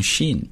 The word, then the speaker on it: Sean: 我们来看看他啊，这个人的名字呢叫 Sean Parker。注意一下，S-E-A-N 这个名字在英语当中实际是非常常见的，很多人读成 Sean 不对啊，读 Sean，